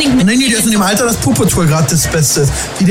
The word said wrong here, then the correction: Paw Patrol: Wie du das gerade ausgesprochen hast. Das heißt Paw Patrol. Du hast gerade Puppetour.